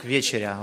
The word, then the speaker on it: вечеря: Кстати, правильное ударение на первый слог «вечеря». Во всех падежах и во множественном числе всегда на первый слог «вечеря». А если глагол «вечерить» на второй слог.